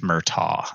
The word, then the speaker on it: Murtaugh: And the vehicle depend on the genre that, you know, when we're, I was calling him Murdoch and he's Murtaugh.